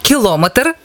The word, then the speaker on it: кілометр: Як правильно, кілометр чи кілометр? Наголос падає на останній склад.